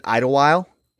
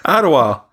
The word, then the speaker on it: Idlewild: It is so Pittsburgh to pronounce it Idlewild. There's a D on the end, but we all just collectively agreed, no thank you, on that D. – Idlewild.